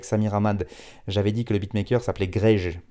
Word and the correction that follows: Grej: parce que ça s'écrivait G-R-E-J. Et en fait, il m'a envoyé un message pour me dire que ça se prononce Grey, parce que c'est, voilà, c'est comme ça qu'on le prononce en Albanie, et il est d'origine albanaise, donc voilà, c'est, il m'a apporté cette rectification et j'ai voulu la faire.